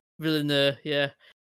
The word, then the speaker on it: Villeneuve: Denis Villeneuve, I think. – Yeah, Villeneuve, yeah.